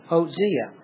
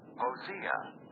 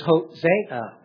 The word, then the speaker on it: Hosea: And actually, you can see in your notes, there's two pronunciations. The one I'm using is the first one, Hosea. And note this. – Hosea. – Crank up the volume a little bit. – Hosea. – You see how they pronounce Hosea. Now, you might say the second pronunciation is Hosea. So that's the second one.